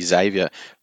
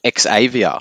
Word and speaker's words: Xavier: Thank God you all said his name correctly, bloody Xavier. Michael Ennis calls him Xavier, like he's in the X-Men.